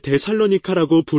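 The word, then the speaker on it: 테살로니키 (Θεσσαλονίκη): 나는 그리스 출신의 한 사람과 이야기를 나눴는데 그 사람은 아니요, 테살로니키입니다. 그러니 당신이 부르고 싶은 대로 부르세요. 나는 그것을 데살로니카라고 부릅니다. 왜인지는 모르겠지만 발음하는 방법은 다양해요.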